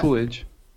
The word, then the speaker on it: Coolidge: Oh my God, how do you pronounce her last name? – Coolige, right? – Coolidge, right? – Oh yeah, my God. Coolidge.